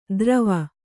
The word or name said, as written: ದ್ರವ